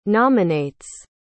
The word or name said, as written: nominates